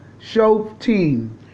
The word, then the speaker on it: Shoftim: It's spelled S-H-O-F-T-I-M, Shoftim.